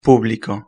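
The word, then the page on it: publico: Spanish (Spain)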